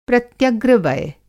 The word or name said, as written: प्रत्यग्रवय